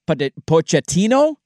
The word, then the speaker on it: Pochettino: Mauricio Pochettino. Is that how you say his last name? The manager? – Close enough. – We'll allow it.